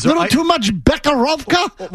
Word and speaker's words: Becherovka: Becherovka.